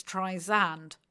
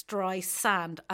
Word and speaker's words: Streisand: So I have always assumed it was, although it's S-A-N-D, sand, I've always assumed it was Streisand. It isn't. It's Streisand, as in things you find on the beach.